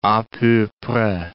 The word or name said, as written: a peu pres